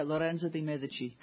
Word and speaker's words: Lorenzo de Medici: The report is that the Instituto Lorenzo de Medici, is that how I pronounce it? – Yeah, Lorenzo de Medici.